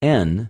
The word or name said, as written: ἐν